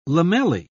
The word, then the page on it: lamellae: British English